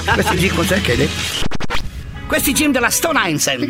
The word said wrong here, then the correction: Stone Island: Questi jeans della Stone Islands. – Stone Islands. – Ma perché scrivono come si legge? – Si chiama così, Stone Island.